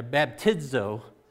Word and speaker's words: baptizo: That word baptize comes from the Greek word baptizo. Actually, that's not the correct pronunciation. It's got like a D sound in there, baptizo.